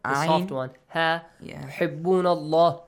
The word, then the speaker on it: الله: تحبون الله. The letter lam in the word Allah. – It's pronounced heavily. – Heavily.